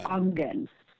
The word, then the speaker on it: Aangen: She's the founder and executive director, and here's another one that requires pronunciation, A-A-N-G-N. – A-A-N-G-E-N. – E-E-N. – Aangen.